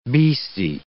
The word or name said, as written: beastie